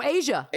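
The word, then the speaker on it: A'ja: And so cut to the celeb game, and I think it's I always mispronounce her name. Ajay Wilson, right? – Oh, Asia. – Asia Wilson.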